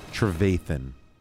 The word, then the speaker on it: Trevathan: Travatan, I might be saying his name wrong. – Trevathan. From the Bears. – How am I saying it? – Trevathan. – Trevathan.